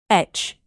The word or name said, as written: etch